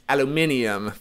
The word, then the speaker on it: aluminium: But in American and Canadian English, you're talking aluminum. For everyone else, it's aluminium.